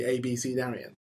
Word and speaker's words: abecedarian: And it's spelt A B E C E D A R I A N. And it's literally ABC Darien.